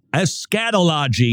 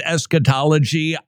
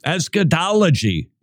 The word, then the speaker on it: eschatology: You say, Friel, isn't that eschatology? I like to put the accent on the wrong syllable because then it makes it sound like I might have inside knowledge you don't, and that would make me seem smarter.